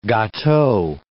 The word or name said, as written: gateau